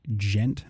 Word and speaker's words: djent: Yeah, so this is a Swedish djent band. It's spelled D-J-E-N-T.